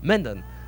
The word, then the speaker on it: Mendon: É essa a pronúncia? Mendon? – Essa aí, Mendon.